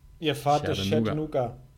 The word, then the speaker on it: Chattanooga: Und das ist eine Stadt in Tennessee, Chattanooga.